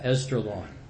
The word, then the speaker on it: Esdraelon: Or Esdraelon, yeah, Esdraelon. Got to say it right.